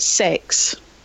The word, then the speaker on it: CeX: I saw an advert for them on the telly a couple of days ago, and they pronounce it sex.